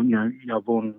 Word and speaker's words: YABUN: I wanted to recenter the fact that the name like YABUN or YABUN, I think as it would more accurately be pronounced, its meaning comes from being in the Sydney language, song to a beat or music to a beat.